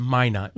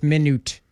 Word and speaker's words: Minot: It's Minot.